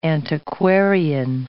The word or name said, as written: antiquarian